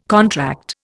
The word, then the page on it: contract: Indian